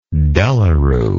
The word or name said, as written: de la Rue